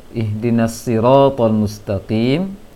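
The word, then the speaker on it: ihdinas siratal mustaqim (اهدنا الصراط المستقيم): Untuk resian yang lain dari bacaan Ananda Najma itu pengucapan huruf sad, ihdinas siratal mustaqim di surah Al-Fatihah, terbaca seperti shin tadi, ihdinash shiratal mustaqim. Jadi shad ini coba diusahakan saja baca berlatih untuk sin yang ditebalkan,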